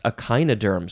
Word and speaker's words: echinoderm: Not pickle, but prickle, as in echinoderm, or would it be echinoderm? Yes, echinoderm. So I think the pronunciation would be echine or echino for the prefix.